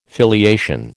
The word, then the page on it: filiation: American English